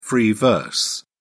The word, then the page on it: free verse: British English